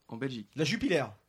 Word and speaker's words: Jupiler: Oh, prononciation la Non, ça se prononce Jupiler.